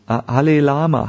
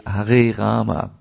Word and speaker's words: Hare Rama: Now, as far as the pronunciation of the holy name, I was with Chinese devotees who chanted Hare Lama, Hare Lama, because they can't say Hare Rama.